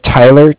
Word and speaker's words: Tyler: Yeah, my name is Tyler, T-Y-L-E-R. And I'm calling with Cardiff. It's spelled C-A-R-D-I-F-F, as in Frank Frank.